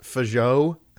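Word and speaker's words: Fagot: But I'm pretty sure it's pronounced Fajot.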